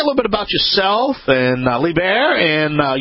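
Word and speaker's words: Liebherr: You're close. It's LeBaer, but it depends on if you ask an American or a German. But if you stick with LeBaer, you're pretty close.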